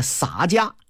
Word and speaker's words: 洒家: 这个字具体怎么念有争议，我看这个人文版的《水浒传》里头有注音，说这字啊念洒，哎，洒家。